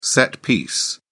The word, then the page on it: set piece: British English